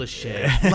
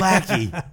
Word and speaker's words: Lachey: It would have been hot. – Better if you pronounced it correct. Yes, his hot. Name was Nick Lachey. – I know it was Nick Lachey. – Lackey. You said it like I thought, like I saw it.